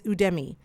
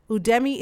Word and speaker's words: Udemy: Next on my list is Udemy. Udemy is spelled U-D-E-M-Y, and it's a learning platform that allows you to purchase and take courses on various subjects.